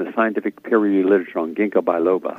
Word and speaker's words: ginkgo biloba: Ginkgo biloba. – Biloba. – G-I-N-K-G-O, ginkgo biloba, B-I L-O-B-A.